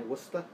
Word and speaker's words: Worcester: Worcester, mm-hmm. as I learned that's how it's pronounced. as I was very harshly bullied for saying Worcester the first time I read it on paper, because I'm not from here.